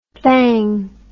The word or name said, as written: thang